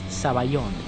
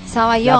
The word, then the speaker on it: sabayón: siempre me criticaba porque es zaballón. Sabayón. la pronunciación correcta.